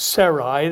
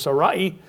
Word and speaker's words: Sarai: Now, we normally, well, we'll probably pronounce it Sarai. The actual pronunciation is Sarai.